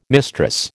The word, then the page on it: mistress: American English